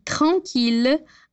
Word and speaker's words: tranquille: No entanto, para as palavras mille, ville e tranquille, a gente vai pronunciar os dois L's com L, normal.